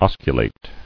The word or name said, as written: Osculate